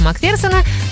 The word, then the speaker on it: Макферсона: Идея оказалась настолько удачной, что сегодня подвеска Макферсона, так правильно ставится ударение по-русски, стоит на огромном количестве автомобилей по всему миру.